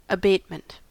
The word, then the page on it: abatement: American English